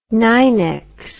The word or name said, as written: nynex